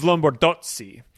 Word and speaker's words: Lombardozzi: which in according to my Italian professor in college would have probably instructed us to say Steve Lombardozzi. When there's a double letter, you kind of pause right before it.